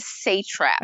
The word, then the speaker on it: satrap: how do you pronounce that word? Sat? – In the Grover says satrap. – Okay, see, I say satrap, but satrap.